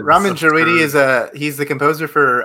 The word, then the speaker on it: Ramin Djawadi: I don't know how to say his name. It's Ramin Djawadi. – I'm going to give it to you because it's Ramin Djawadi.